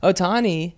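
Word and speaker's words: Ohtani: Otani? Did I say that right? – Yeah. – Otani, he was playing.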